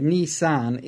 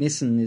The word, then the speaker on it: Nissan: It's two Japanese words, one meaning ni, which is two, and san meaning three. So Nissan is the actual correct pronunciation. However, we are an Australian. Nissan is the predominant.